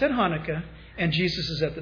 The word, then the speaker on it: Hanukkah: That is Cheslev 25th, or what we call Hanukkah, or Hanukkah. You got to spit when you say it if you say it right.